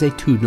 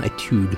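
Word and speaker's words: Étude: And it is Etude, not Etude. I always thought you pronounced it Etude.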